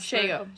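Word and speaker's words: Shego: The Kim Possible, and I can't pronounce Shago.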